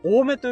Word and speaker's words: 青梅: いや、あのさ、このボルダリングジムって、あ、ケタロさんが、えっ、ー、と、青梅と書いて、多めと読みますが、あ、多め、多めの河原で岩に登りましたってすげえ。